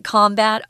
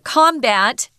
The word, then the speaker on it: combat: Uh, combat as a noun is pronounced combat with the emphasis on the first syllable. But when we use it as a verb, we say combat and it kind of changes the the pronunciation. I was going to say fine, combat and combat.